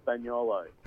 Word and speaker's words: Spagnolo: Spagnolo.